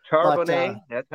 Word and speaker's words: Charbonnet: Charbonnet, I was getting ready to say that, and I would have said it wrong. So I'm glad that's how I would say it. It looks French to me.